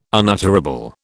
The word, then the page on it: unutterable: British English